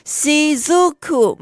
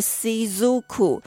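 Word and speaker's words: 雫: 哎，对对，有人讲，有人讲到了 shizuku，没错，它的在日文的发音叫做 shizuku。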